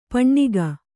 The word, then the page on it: ಪೆಣ್ಣುಗ: kannada